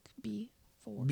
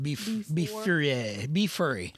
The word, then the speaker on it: Befour: I almost used a song called Before, but it's spelled B-E-F-O-U-R, like before. Be furry. – No, it's before.